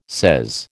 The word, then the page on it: sez: American English